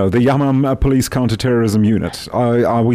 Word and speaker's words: Yamam: Mike, it would seem the unit that, for example, that was involved with the special ops, it's called the Yamam Police Counterterrorism Unit. Have I pronounced that correctly? – Correctly, exactly.